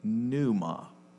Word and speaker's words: pneuma: If you were to bring this over, usually we put a silent P in front of it, P-N-U-E-M-A, pneuma.